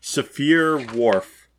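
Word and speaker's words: Sapir-Whorf: He definitely brings up the Saphir-Whorf. Thank you for teaching me how to pronounce that. – Saphir-Whorf.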